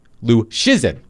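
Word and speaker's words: Luszczyszyn: By the way, let me spell Dom's last name for all of you if you have not encountered Dom's work at The Athletic. Lucczyzyn. Pronounced Lucchesin. He is going to...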